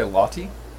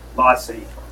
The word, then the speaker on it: Lassi: I'm definitely going to order a mango lassi as our special drink, which... By the way, sorry, did you just say Lottie? – Lassi. – Oh, Lassi.